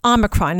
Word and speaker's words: Omicron: Yeah, there's no extra N. – Omicron.